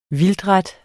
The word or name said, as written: vildtret